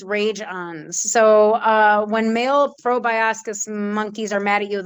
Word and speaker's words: proboscis: So I don't know how to pronounce this word, but it's P-R-O-B-O-S-C-I-S. Proboscis. – Proboscis.